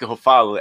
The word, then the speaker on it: Ruffalo: Não, porque olha só, é o, é o, é o, é o Mark Ruffalo, é a pronúncia certa? Mark é Ruffalo.